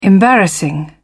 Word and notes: embarrassing: British English

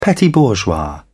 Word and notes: petit-bourgeois: British English

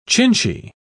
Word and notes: chinchy: British English

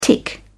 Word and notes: tick over: British English

